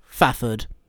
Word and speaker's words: Fafnir: It's not, as I was worried, Fafnir. It's definitely not Fufuf, as I speculated last time. It's Fafnir, the most obvious way of pronouncing it.